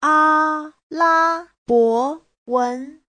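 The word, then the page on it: 阿拉伯文: a1la1bo2wen2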